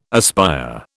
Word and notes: aspire: British English

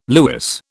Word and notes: lewis: British English

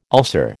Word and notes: ulcer: American English

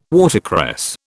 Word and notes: watercress: British English

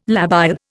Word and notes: labile: Indian

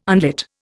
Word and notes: unlit: Indian

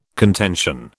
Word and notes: contention: British English

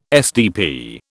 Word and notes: SDP: British English

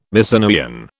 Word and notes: Mycenaean: British English

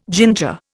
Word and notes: ginger: Indian

